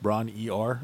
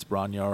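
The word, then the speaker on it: Bron-Yr-Aur: Bronyar, however it's pronounced, Bron-E-R, it's not that. It's Bronyar or Bromyar, I think. It's Welsh.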